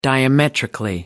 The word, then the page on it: diametrically: American English